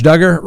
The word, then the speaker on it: Duggar: But Josh Duggar, rhymes with hugger.